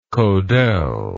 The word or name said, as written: Kodel